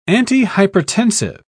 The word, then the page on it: antihypertensive: British English